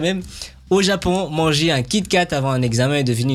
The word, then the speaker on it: Kit Kat: Au Japon, Kit Kat se prononce presque comme Kito Katsu, qui veut dire tu vas réussir à coup sûr.